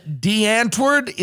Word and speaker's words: Die Antwoord: band Die Antwoord. Is that how you pronounce it? – That's right.